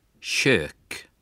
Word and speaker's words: kök: Трудный звук, нечто среднее между русскими «че» и «ща» в таких словах, как «кухня» или «мясо», когда буква «к» стоит перед мягкими гласными. Повторяйте. «Кухня». – «Щек».